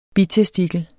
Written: bitestikel

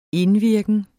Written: indvirken

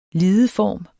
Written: lideform

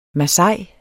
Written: masai

